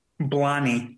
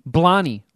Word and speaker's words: Blathnaid: Blani. – Blani. Like Bonnie with an L thrown into it? – Yeah. Like Blarney, but with no R. – All right, here is how you pronounce B-L-A-T-H-N-A-I-D.